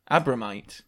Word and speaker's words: Abromeit: Franz Abromiet? – Can you spell it? – Abromiet. A-B-R-O-M-E-I-T. – Abromite. – Okay, Franz Abromite.